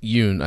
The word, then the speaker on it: Yeun: Steven Yeun, how do you say his name? – Yoon, I think it's Steven Yoon.